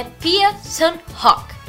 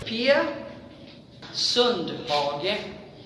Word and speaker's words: Pia Sundhage: a gente aprendeu que o nome da Pia é Pia Sundhage. – Pia Sundhage.